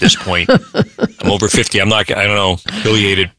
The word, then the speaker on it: pileated: But I say pileated, and I just learned or maybe relearned that that's not correct. You know, some things like plover, clover. – Oh, I'm glad I said it right. – Yeah, you said it right. And I looked it up, and it comes from a Latin word, pileus, meaning cap.